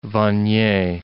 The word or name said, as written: Vanier